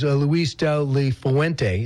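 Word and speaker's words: Luis de la Fuente: He said as soon as he was done playing, he went to Spain to a coaching academy, and his coach was Luis del Fuente.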